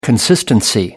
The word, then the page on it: consistency: American English